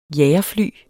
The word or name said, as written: jagerfly